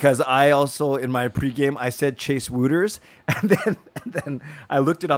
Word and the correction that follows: Wouters: Guess what it's actually pronounced? – Is it actually Wouters? – It's Waters. Like. – Okay. Here's the thing. So yeah, I said Wouters in my rookie camp video, and then yeah.